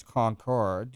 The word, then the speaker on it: Concord: And that's the way we pronounce Concord, you know, Concord, and that's where the capital is.